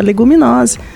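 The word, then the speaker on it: Leguminosae: é leguminose. Então, eu estou aqui pronunciando leguminose como latim, né?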